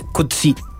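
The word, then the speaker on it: Coetzee: Claro, es en Afrikaans, el apellido, una derivación del neerlandés, digamos, y la pronunciación que prefiere el autor es Kutsi.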